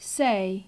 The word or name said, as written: say